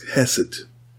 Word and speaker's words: hesed: Listen, the Hebrew word translated as love in this verse, and I'm going to use the English pronunciation, is hesed.